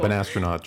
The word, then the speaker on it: Banastronaut: That's how you say it, right? – Hello. Yes. Okay, yeah, cool. Banastronaut, just like you think it sounds.